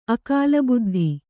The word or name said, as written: ಅಕಾಲಬುದ್ಧಿ